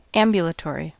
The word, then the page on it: ambulatory: American English